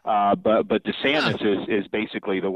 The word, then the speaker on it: DeSantis: which he says DeSantis and everybody else says DeSantis.